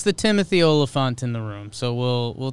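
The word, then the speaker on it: Olyphant: Oliphant sounds like elephant.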